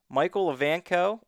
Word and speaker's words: Michael Evanko: With us this week, Michael Evanko.